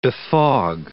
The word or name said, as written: befog